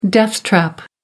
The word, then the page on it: death trap: American English